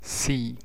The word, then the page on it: C: letter name; /siː/; /si/